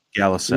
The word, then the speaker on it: Galuside: Did I pronounce that right? – I believe it's Galuside. – Galuside. – You're close.